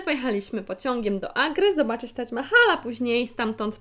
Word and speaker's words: Taj Mahal: grobowiec Taj Mahal, i od razu mówię, że wymawia się poprawnie właśnie tą nazwę, nie Taj Mahal, tylko Taj Mahal,